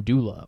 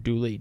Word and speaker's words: Dula: His name is Tom Dula or Tom Dooley, depending on how you pronounced it, because of the spelling.